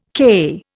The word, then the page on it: CAI: Indian